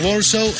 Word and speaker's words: Warsaw: Warsaw sería en inglés, creo que esto habría que pronunciarlo con V.